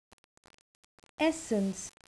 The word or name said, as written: essence